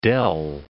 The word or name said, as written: dell